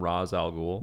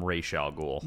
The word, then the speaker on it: Ra's al Ghul: You know what's funny, by the way, is that we use the proper pronunciation, Ra's al Ghul.